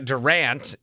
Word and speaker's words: Durant: Durant is how he pronounces it.